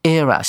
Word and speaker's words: era: era 像我们字典上的音标所标的，另外一个念法是大多数的美国人呢很喜欢念的方法，把它念成一的发音哈，这两种发音都是可以的。